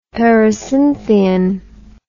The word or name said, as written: pericynthion